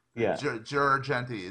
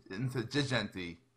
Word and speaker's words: Girgenti: Matter of fact, when I was in Venice, the guy goes, "You say your name wrong. It's not Girgenti. It's Girgenti."